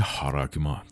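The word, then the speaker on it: هاراگما (χάραγμα): در زبان یونانی کلمه این نشان یا همون مارک به صورت هاراگما تلفظ میشه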